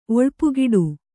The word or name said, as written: ಒಳ್ಪುಗಿಡು